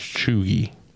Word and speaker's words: cheugy: Okay, so this one is Chugy, spelled C-H-E-U-G-Y, pronounced Chugy. – C-H-E-U-G-Y.